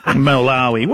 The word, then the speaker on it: Malawi: They smashed Malala. How do you say that? Malawi.